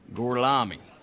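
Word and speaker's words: Gorlami: Gorlomi? Lo pronuncio correttamente? – Sì, corretto. – Gorlomi? Per cortesia, me lo ripeti ancora. – Gorlomi.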